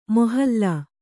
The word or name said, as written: ಮೊಹಲ್ಲಾ